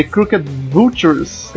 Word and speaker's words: Crooked Vultures: som do The Crooked Vultures, eu não sei pronunciar isso, eu pronunciei certo sem é querer. É isso aí mesmo.